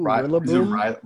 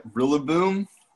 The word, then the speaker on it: Rillaboom: Ooh, Rillaboom. – Rillaboom? Rillaboom? How do you say that? – How do you like Rillaboom?